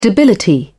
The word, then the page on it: debility: British English